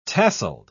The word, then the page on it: tasselled: British English